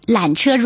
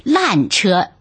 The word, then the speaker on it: 缆车: 缆车如果讲得不好就成了烂车，是声调的问题。缆车第三声变到第四声了，就是烂，破破烂烂的。